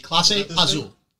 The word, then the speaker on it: Clase Azul: Isn't it pronounced Clase Azul, not Clase Azul? – Clase. – Have I been saying it wrong? – Clase. C-L-A-S-E. Clase Azul.